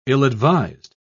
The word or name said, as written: ill-advised